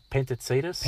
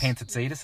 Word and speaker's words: Pantazidis: Have I pronounced that? Where's the Greek god of? – Where's Jake when you need him? I'd say Pantazidis. – Pantazidis. That sounds good.